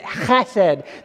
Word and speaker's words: chesed: So you got to get guttural, go, and then chesed. That's the word. You got to say it right.